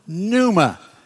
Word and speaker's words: pneuma: Pneumonia, P-N-E-U-M-A. That's the same Greek word, pneuma. It's pneuma, pneuma, that P is silent.